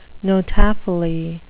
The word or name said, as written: notaphily